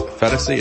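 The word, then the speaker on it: Phetasy: As one person tweeted, I think you pronounce her name, the last name, as Fetasy. It's P-H-E-T-A-S-Y. Bridget Fetasy, I guess.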